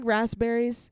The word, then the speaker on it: raspberries: Like, I don't like raspberries because, did I say that word? Raspberries. – Raspberries. Well, most people pronounce it raz.